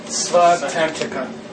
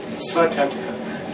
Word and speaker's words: Svatantrika: Svatantrika. Svatantrika. A lot of people mix this up with Svatantrika. – Yeah, I would. – And our teacher pronounces them both pretty much the same way we are. Svatantrika.